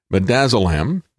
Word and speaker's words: midazolam: NHS documents confirm staff was ordered to administer midazolam. That's how you pronounce this. I looked it up. It's interesting, isn't it? It's like me, like dazzle.